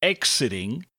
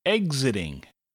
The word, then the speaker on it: exiting: But in this case, it sounds more like a G and Z together. So instead of exiting, it's exiting. Exiting.